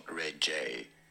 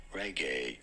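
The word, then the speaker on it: Regé-Jean: Yes, it is not reggae, but rather reggae. Jean. – I did it! – Pretty straightforward. Reggae. Jean Page. – Fuck. – And now you know. – That's amazing. Reggae Jean Page.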